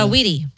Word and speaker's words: Saweetie: Saweetie.